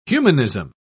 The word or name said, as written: humanism